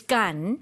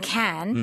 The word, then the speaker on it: Cannes: C-A-N-N-E-S C-A-N-N-E-S. Now, in French, it's gun. – Gun. – But of course, as you can tell from what I've been saying, can, mm-hmm. – a lot of Americans say can.